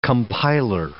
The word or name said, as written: compiler